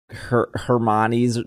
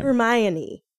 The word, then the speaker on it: Hermione: Is that like the thing where everyone says that Hermione's name wrong? – Oh, okay, no, we cannot have this conversation.